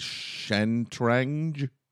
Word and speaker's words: Shatranj: In the Muslim world, the game became known as Shatranj. – Yes. Perfect pronunciation.